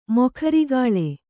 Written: ಮೊಖರಿಗಾಳೆ

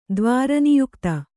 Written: ದ್ವಾರನಿಯುಕ್ತ

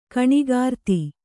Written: ಗುಣಾಕೃತಿ

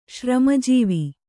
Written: ಶ್ರಮಜೀವಿ

